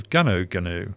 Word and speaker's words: Gunu: There's a place up in New South Wales called, spelled Gunu, but it's pronounced by the locals as Ganu.